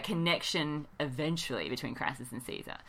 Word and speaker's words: Caesar: Say the name Caesar. – Ooh. I tingle with recognition. – Gaius Julius Caesar. – Woo! I do declare. – Or should we do the hard C? No. – Kaiser.